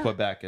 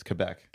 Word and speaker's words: Quebec: Yeah, it's not Quebec, it's Quebec.